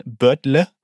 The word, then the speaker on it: Birdle: Also deutsch ausgesprochen Birdle, also B-I-R-D-L-E.